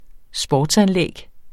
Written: sportsanlæg

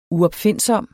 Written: uopfindsom

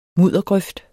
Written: muddergrøft

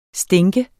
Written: stinker

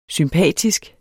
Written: sympatisk

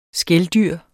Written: skældyr

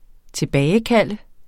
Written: tilbagekalde

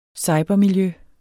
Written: cybermiljø

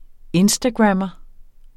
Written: instagrammer

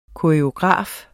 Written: koreograf

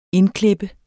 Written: indklip

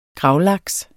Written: gravlaks

